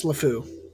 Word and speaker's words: LeFou: Yes, LeFou.